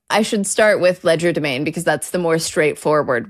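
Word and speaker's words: legerdemain: And we pronounce it in this sort of obnoxiously English way. – Ledger domain.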